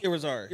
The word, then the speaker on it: Irizarry: How do you pronounce the last name? Is, Izar? I think is Irizaria is Irizarry. Irizarry.